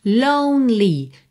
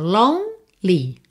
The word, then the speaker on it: lonely: Lembrar de não pronunciar as letrinhas E que estão pintadas de cinza. Então, olha, no more, termina o som no R. Lonely, do N você vai pro L. Pensa, ó, lonely, lonely, ok?